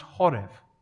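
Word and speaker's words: horev (חֹרֶב): The Hebrew word for ruin is used to describe the temple in verse 4, and it's pronounced harev in Hebrew. The word for ruin used to describe the temple is pronounced harev, but the word used for drought in verse 11 to describe the conditions of the environment that's around them is pronounced horev. Horev.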